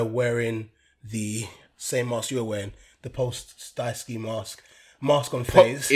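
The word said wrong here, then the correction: Pooh Shiesty: Listen, first you couldn't pronounce words. Now you can't pronounce rapper's name. – I don't know his name. – It's Poo Shiesty.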